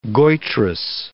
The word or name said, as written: goitrous